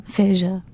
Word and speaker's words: aphasia: Someone like Michel Paradis, the linguist from Canada, who studies bilingual people with, is it aphasia? Is that how you say it? – Yeah, aphasia. – Aphasia.